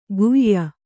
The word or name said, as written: ಗುಯ್ಯ